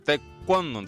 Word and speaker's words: Taekwondo: Taekwondo, entonces sería la pronunciación.